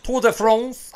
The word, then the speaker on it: Tour de France: And did I say that right? Tour de France? – Tour de France. As an American trying to speak French, yes.